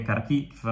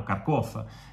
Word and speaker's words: Kharkiv: Ricordo che Kharkiv è una città, o Kharkov, dipende dalla pronuncia, enorme, 1,5 milioni di abitanti.